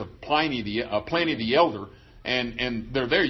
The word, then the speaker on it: Pliny: You want to translate that, you want to pronounce that Pliny, but there's no E after the N, so it's Pliny.